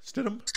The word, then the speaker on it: Stidham: Stidham.